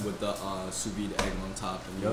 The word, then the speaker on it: sous vide: Sous vide.